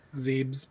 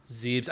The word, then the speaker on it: Zebes: Like I remember Super Metroid, Zebus or however you pronounce it. – Very Zebes. What is it? – Zebes. – Zebes. I was wrong both ways.